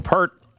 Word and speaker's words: Peart: Rush is going to be touring again, and that might be surprising to some people because their drummer, Neil Peart, How do you say his name? Because some people say Peart. – It different. Peart. Yeah. Died. I always say Peart, but I know that's wrong.